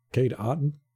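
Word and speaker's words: Otton: He said, yeah, my name's Cade Oten.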